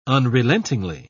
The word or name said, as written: unrelentingly